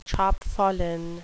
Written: chapfallen

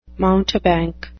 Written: mountebank

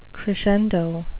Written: crescendo

